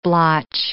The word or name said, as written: blotch